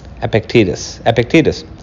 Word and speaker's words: Epictetus: Epictetus.